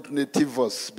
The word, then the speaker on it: nativus: According to the Bible, as way of introduction, according to Bible scholars and historians, the term nativity comes from the Latin word Nativus. Sorry. I'm going to call it nativus, but if you are pronouncing it the Latin way, it's like nativus.